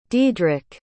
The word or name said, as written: diedrick